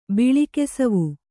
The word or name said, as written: ಬಿಳಿಕೆಸವು